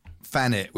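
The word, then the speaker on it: Thanet: You know, like people talk about Fannet with an F. Of course, it's not spelt that way, but that's how it's pronounced.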